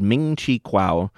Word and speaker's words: Ming Chi Kuo: He's an analyst called Ming Chi Kuo, and did I say that wrong? – No.